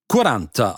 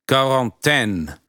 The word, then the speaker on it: Quarantäne: Die Italiener sprechen die Quarantäne, ihre Quarantäne, wie Quaranta aus. Wir kennen diese Aussprache des Digraphen Q von Wörtern lateinischer Herkunft wie Quader, Quadrat, Qualität, Quantität, Quart, Quartier, Quint oder Quote. Dass wir ausgerechnet die Quarantäne unitalienisch aussprechen, hat einen einfachen Grund. Wir haben die Quarantäne von den Franzosen entlehnt aus Quarantaine, einer Ableitung von quarante, 40. Den einzigen Vorwurf, den wir uns machen dürfen, ist die Aussprache des im Französischen verschluckten finalen E. Am anlautenden K hingegen gibt es nichts zu bekritteln.